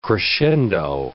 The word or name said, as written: crescendo